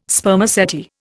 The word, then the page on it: Spermaceti: Indian